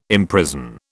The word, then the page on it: imprison: British English